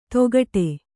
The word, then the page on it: ತೊಗಟೆ: togaṭe